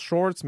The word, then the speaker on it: Sjokz: Apparemment que ça se prononce Shorts, mais ça s'écrit genre S-J-O-K-Z.